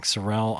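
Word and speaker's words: Sorelle Amore: So Sorrel? – Yeah. – Is it Sorrel? – Yeah. – I always think that sounds wrong. Sorrel Amor, she also did it.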